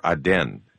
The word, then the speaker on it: Aden: Gulf of Aden, or Aden, if you want to pronounce it that way.